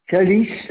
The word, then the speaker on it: Kalis: Kalis, alors ça s'écrit K-A-L-I-S.